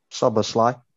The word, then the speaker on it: Szoboszlai: Dominic Szoboszlai.